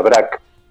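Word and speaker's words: Brač: Busca ahí la de Brac, se escribe así como suena, Brac.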